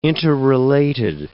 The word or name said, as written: interrelated